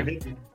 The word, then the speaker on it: Jim Higdon: Jim Higdon.